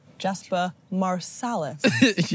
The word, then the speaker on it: Jasper Marsalis: Or, aka, how do I say your last name with an English accent? Jasper Marsalis. – Yes. – Correct pronunciation? – Correct.